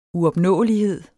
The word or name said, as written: uopnåelighed